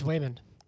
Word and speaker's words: Wayman: Meanwhile, is it Wayman? – Raymond. – Well, it's spelled with a W. – It's Wayman. I apologize.